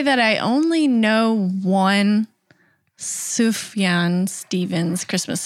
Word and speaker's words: Sufjan: He pronounces it Sufjan. And it was like the first time that anyone had heard his name said out loud. – Yeah. – And you could hear the whole audience abuzz with finally knowing how to say his first name.